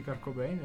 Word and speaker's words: Kurt Cobain: Sì, Kurt Cobain, ho detto bene. – Sì, Kurt Cobain, ho detto benissimo.